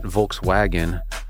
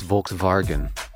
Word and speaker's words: Volkswagen: It's not Volkswagen. It's Volkswagen. That's the correct way to say it.